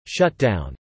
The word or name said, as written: shutdown